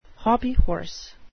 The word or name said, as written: hobbyhorse